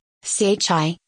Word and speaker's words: CHI: CHI, pronounced as Chi, is the Consciousness and Healing Initiative created by Dr. Jain.